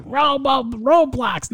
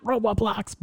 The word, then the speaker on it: Roblox: Don't say that wrong. Every kid will say Roblox, not Roblox.